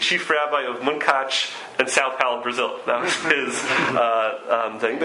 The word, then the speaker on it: São Paulo: São Paulo? – São Paulo in Brazil.